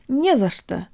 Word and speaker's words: не за что: guys, today you'll learn how to pronounce the phrase не за что, or don't mention it. Although there are three little words in this phrase, it functions just like a single word, with only one stressed syllable, не.